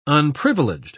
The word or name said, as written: unprivileged